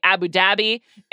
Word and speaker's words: Abu Dhabi: His last race was 2 weeks ago, the final race of the 2022 season in Abu Dhabi, or as the British say, Abu Dhabi.